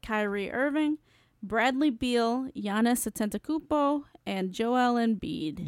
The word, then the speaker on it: Antetokounmpo: You pronounce that so well. – Antetokounmpo? – Yeah. I've tried to say it, and I feel like I can't. – Say it. – Antetokounmpo. – That's pretty good.